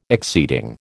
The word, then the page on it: exceeding: American English